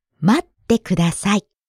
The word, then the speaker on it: 待って: The first word is not 待って but 待って。 – Right, there's a stop sound before that. て、待って。